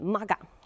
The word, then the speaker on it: MAGA: 当然会引起中期选举的影响啊，还有包括现在特朗普的忠实粉丝阵营里面 MAGA。我之前呢一直常念成 MEGA，可能是对一个游戏啊，因为以前常玩那个游戏的影响啊，他这个忠诚的粉丝 MAGA 团呢内部发生分裂了，还互相指责对方你是叛徒。